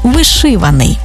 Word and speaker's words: вишиваний: Отже, цілодобовий та щодобовий, 100-відсотковий, ринковий, одноразовий та багаторазовий, наскрізний та вишиваний.